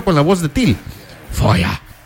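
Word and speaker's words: Feuer: Bueno, es que hay varias, pero Fire, esa es la que puedo pronunciar. – No. Repeat after me, bye. Repeat after me. Se dice Foya.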